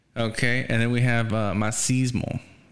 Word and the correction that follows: Macizo: Or I guess that's how you say it. – Macizo. – Macizo. Okay, okay, okay.